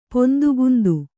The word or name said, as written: ಪೊಂದುಗುಂದು